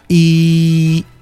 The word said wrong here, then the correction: Eileen: Eileen Vera, Eileen, así se pronuncia.